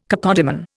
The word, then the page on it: cacodemon: Indian